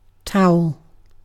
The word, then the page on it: towel: British English